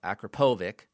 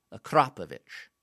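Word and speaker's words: Akrapovič: Other media outlets are calling it Akrapovic. No. Akrapovich.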